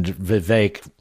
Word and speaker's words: Vivek: Rhonda, and Vivek, rhymes with cake, just hate fucking each other.